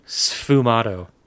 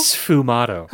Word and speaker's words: sfumato: Sfumato. – Sfumato? – Sfumato. – How do you spell that? – S-F-U-M-A-T-O. – S-F? – Uh-huh. Sfumato. It probably comes from some old Italian language, like, you know, Sforza.